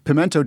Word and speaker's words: pimento: Pimento does end in O, but it's three syllables.